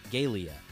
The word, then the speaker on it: Gaelia: G-A-E-L-I-A. Galia.